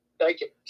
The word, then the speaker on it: Steichen: Uh, is this pronounced Steinen? I almost forgot how to pronounce his name now. Stecken? Steinen? Steichen. Steichen. Yes.